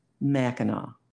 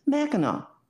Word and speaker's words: Mackinac: The word is pronounced Mackinac no matter if it ends with an A-C or an A-W, and it means great connection to land or place.